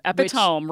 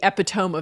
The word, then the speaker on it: epitome: I think one of the words that often trips people up is the word spelled E-P-I-T-O-M-E. – Oh, epitome, right? – Which sure looks like it should be epitome. And I remember this ad when I was young for a local car dealership where, as a joke, someone referred to the epitome of excellence. The word is, in standard pronunciation, epitome.